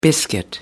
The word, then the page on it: biscuit: American English